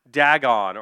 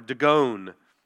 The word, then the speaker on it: Dagon: And they gather together to offer a sacrifice to their god, Dagon, or Dagon, depending on how you want to pronounce it.